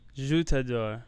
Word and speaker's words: Je t'adore: Je tu adore, is that right? I love you. Do you know? – I took 3 years of French and can barely speak a fucking word of it. – Je t'adore. That's how you say it.